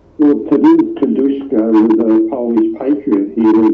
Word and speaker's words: Kosciuszko: But of course, the other significant name in all of this, of course, is Kosciuszko, or for our Polish friends, they'd be much closer to pronouncing it Kosciuszko, but we'll let that one rest for a moment.